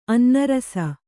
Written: ಅನ್ನರಸ

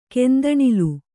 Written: ಕೆಂದಣಿಲು

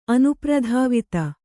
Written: ಅನುಪ್ರಧಾವಿತ

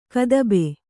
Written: ಕದಬೆ

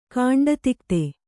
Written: ಖಂಡಿತೋಕ್ತಿ

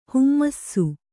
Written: ಹೇಮಿಸು

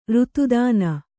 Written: ಋತುದಾನ

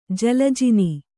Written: ಜಲಜಿನಿ

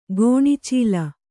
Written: ಕಣ್ಚಲ್ಲ

